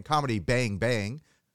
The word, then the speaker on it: Comedy Bang! Bang!: Yes, you're saying it correctly. Thank you so much for stressing the bangs. Most people just say comedy, bang, bang, and then they mumble the bang, bang.